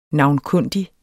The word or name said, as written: NAVNKUNDIGT